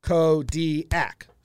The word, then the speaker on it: Kodiak: How many syllables is Kodiak? Kodiak. Three. Three syllables, officially.